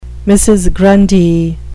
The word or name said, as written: mrs grundy